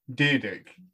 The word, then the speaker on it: Dyrdek: Dyrdek. Yeah. – It's literally Dyrdek. That's how you say it.